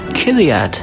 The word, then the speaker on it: chiliad: This week's word is Chiliad. It is spelled C-H-I-L-I-A-D.